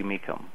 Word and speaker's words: Mecum: They can go to YouTube and type in the words Dudley Mecum. Last name is spelled M-E-C-U-M.